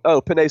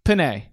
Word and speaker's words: Penei: Oh, Penei Sewell. – Penei.